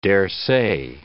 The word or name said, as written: daresay